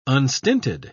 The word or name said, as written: unstinted